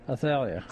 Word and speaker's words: Athaliah: And then her descendant became, and how do you say it? – Athaliah, you say? – Athaliah. – Athaliah, okay. – That's right. Either way.